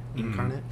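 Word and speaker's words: Inkarnate: Yeah, the program that we use, I use a program called Incarnate, like, but it's spelled like I-N-K, like ink. Incarnate.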